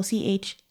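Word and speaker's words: Cheslie: It's spelled Chelsea, but the S comes before the L. So Chesley.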